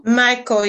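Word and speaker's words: Mico: She's a graduate of, and you're going to have to tell me this, how is it pronounced? It's M-I-C-O. What is that, Michael? – Michael, yes, you got it right.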